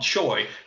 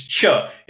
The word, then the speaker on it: Choi: We can call them Choi. But since they're actually playing in Korea, you try to use the correct pronunciation, you know, which is Cha.